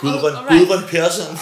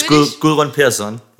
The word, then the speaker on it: Gudrun Persson: Can I see? I can maybe... Gudrun Persson. Gudrun Persson. – All right. Maybe it is how it sounds. – It's Gudrun Persson. Something like that.